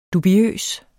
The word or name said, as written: dubiøs